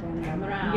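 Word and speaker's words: Damrau: Diana, is it Demerau? – Oh, Demerau.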